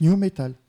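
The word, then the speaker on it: nu metal: Non, il y a, oui, mais ça se prononce new metal.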